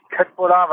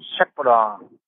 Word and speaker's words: köttbullar: Das heißt nicht Köttbullar, weil das ja nicht korrekt ausgesprochen ist, sondern Schöttbullar.